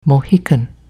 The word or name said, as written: Mohican